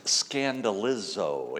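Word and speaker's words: scandalizo (σκανδαλίζω): As I mentioned downstairs in Bible study, in the original language, this cause to sin in the original language is pronounced scandalizo.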